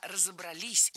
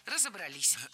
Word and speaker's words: разобрались: Не разобрались, а разобрались. Ударение ставим опять же на последний слог, на окончание, поближе к возвратной части «се». Разобрались.